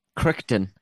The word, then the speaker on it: Crichton: Crichton.